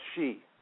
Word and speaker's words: Xi: It's pronounced Xi. Xi is Xi.